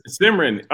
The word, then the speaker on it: Simran: Simran.